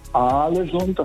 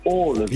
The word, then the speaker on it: Ålesund: Also das heißt nicht Alesund, das heißt Ålesund.